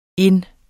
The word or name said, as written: ind